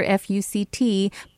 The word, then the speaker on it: FUCT: She said the clothing brand, quote, is pronounced as four letters, one after the other, F-U-C-T.